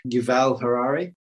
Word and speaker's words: Yuval Harari: And what I mean by abstract thoughts, and brilliantly portrayed in the book Sapiens, and Noel Huer, I can never pronounce his name, Yuval Harari, talks about two different realities.